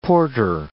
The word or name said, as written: porter